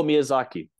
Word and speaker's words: Miyazaki: At the I'm going to butcher this, so forgive me, the Masasaki movie that's coming out. – Oh, Miyazaki thank movie.